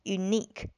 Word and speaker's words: unique: 注意这个词的发音，它的重音呢是在这个中间的 ni 上面 unique。